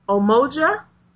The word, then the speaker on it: Umoja: So the first principle is UMOJA, but it looks like it's pronounced Omoja. Omoja.